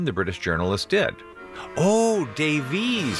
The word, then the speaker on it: Davies: Davies, even though Davis is the common British pronunciation.